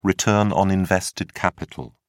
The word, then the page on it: return on invested capital: British English